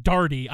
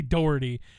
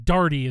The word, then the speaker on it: Doherty: Actually, it's pronounced Darty. I know this. – That's fine. – I don't know why they say Doherty. It's Brad Darty is his name.